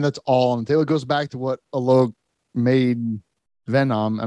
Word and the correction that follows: Menon: I'm not saying that right. Alok. – Menon. Yeah.